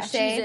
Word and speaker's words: O'Shea: O'Shea Jackson Jr. Am I saying this right? – Yeah. – O'Shea.